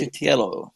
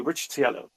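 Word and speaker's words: Riccitiello: Ricciatello. – That's the one. Thank you, Ricciatello.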